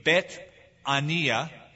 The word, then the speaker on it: Bethania: And Bethany, in Hebrew, would be Bethania.